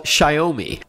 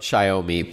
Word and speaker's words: Xiaomi: And they're called Xiaomi. It's spelled X-I-A-O-M-I.